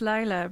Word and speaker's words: Layla: No, it's Lila, but it's written as Layla, so I don't mind at all.